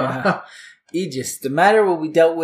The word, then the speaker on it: aegis: The next word is aegis. It's spelled A-E-G-I-S, aegis.